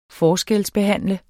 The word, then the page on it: forskelsbehandle: ˈfɒːsgεls-